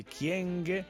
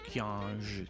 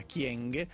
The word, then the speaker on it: Kyenge: le polemiche per le parole del ministro Cécile Kyenge. Io lo pronuncio così, non Kyenge, Kyenge, mi piace più all'africana, Kyenge.